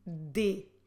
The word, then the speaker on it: dê: Your verb form for the formal you, você, is de. De. Como se escreve de? De é com acento circunflexo. So what you need to do is de with a circumflex on the e.